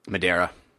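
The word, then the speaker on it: Madureira: So, how do you say Joe's last name? I've never been able to say it. – Madeira. – Madeira? – Yeah.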